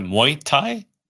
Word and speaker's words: Muay Thai: Is it Muay Thai? – Yeah.